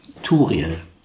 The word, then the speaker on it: Toril: Turil. Man schreibt sie mit O, wird aber Turil ausgesprochen.